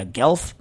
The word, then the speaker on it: Guelph: Guelph.